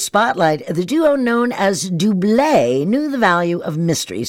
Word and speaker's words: Double: And yes, it is pronounced Dublé, even though it's spelled D-O-U-B-L-E, which in the U.S. is double.